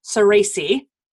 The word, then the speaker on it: Soresi: And my guest today is Gianmarco Saraci.